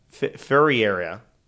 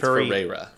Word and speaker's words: Ferreira: He then fatally shot Miroth, her 6-year-old son Jason, and her 22-month-old nephew David Ferriera. – Ferreira. I think it's Ferreira. – Ferreira. Ferreira. – Because Sky Ferreira's name is spelled that way. And she's a singer.